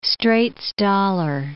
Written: Straits dollar

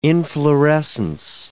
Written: inflorescence

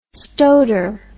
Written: stoiter

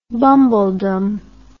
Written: bumbledom

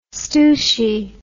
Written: stooshie

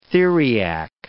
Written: theriac

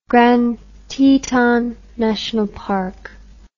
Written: Grand Teton National Park

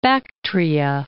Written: Bactria

